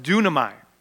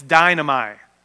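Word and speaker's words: dunamai (δύναμαι): So if you take time and you break down that verse 5, apart from me, it's this Greek word, it's pronounced dunamai. And if you were to say it the way it's spelled, it's dynamai. What does that sound like? – Dynamite.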